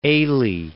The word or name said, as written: Ailey